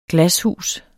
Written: glashus

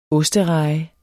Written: ostereje

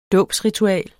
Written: dåbsritual

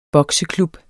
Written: bokseklub

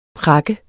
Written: prakke

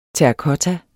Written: terrakotta

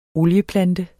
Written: OLIEPLANTE

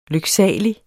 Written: lyksalig

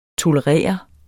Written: tolerere

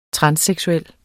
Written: transseksuel

